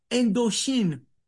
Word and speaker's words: Indochine: From 1911, when he left Indochine, which is the French pronunciation of Indochina, which is what it was called.